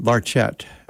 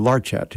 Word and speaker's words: Larchet: His surname is a French name that he anglicized, being Irish, I suppose, or whatever, and pronounced Larchet, or Larchet. He put the accent on the first syllable.